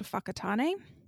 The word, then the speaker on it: Whakatane: Fakari is located off the coast nearest a town called Whakatane. Again, spelled with a W, pronounced like an F.